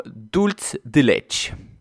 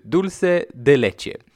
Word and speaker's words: dulce de leche: Många skandinaver tenderar att uttala det som dulce de leche. Men det är ju inte så det ska uttalas utan det heter ju dulce de leche.